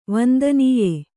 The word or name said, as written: ವಂದನೀಯೆ